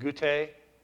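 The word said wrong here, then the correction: Guatay: How's it pronounced? What? Guatai, thank you.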